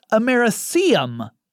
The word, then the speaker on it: americium: So, in the episode I did about smoke detectors, I talked about the radioactive element, americerium 241. Here's the problem. I inserted an extra syllable there, and even in my notes I had it spelled correctly. It's americium 241.